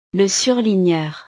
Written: le surligneur

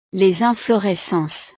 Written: les inflorescences

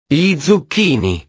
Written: gli zucchini

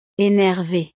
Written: enerve

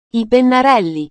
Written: i pennarelli

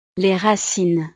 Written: les racines

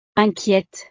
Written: inquiete

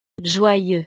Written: joyeux

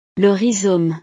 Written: le rhizome

